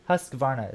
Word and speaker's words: Husqvarna: I'm trying to pronounce the name right because I've heard a lot of people say it a lot of different ways. I think Husqvarna is how you say it.